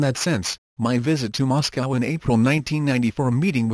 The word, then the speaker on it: Moscow: I said only half-jokingly at the time that Moscow, pronounced in English, sounds similar to us go, and so I had to go.